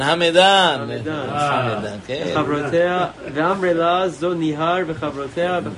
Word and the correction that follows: נהוואן: נהוואן, נהוואן, תקרא טוב. זה נהוואן. זה נהוואן וחברותיה.